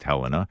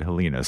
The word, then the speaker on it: Helena: I know that it's pronounced Helena, but for some reason I said Helena.